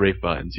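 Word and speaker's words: Ralph Fiennes: I always say his name incorrectly, but it's Rafe Fiennes, yes.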